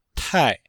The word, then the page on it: tai: tai4